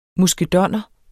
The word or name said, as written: muskedonner